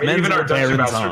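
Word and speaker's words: Menzoberranzan: Well, it's Menzo Baranzan, which really I think is dumb, because I've also always said Menzo Baranzan, but my, like, I have that weird visceral nerd reaction where it's like, I know a thing that you don't.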